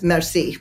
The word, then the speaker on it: merci: It's just merci, merci. It's French for thank you. Merci. I'm super bad at accents, you guys. I took many years of Spanish. I took AP Spanish, and my Spanish accent is not good. But anyways, merci. That's how you say it, right?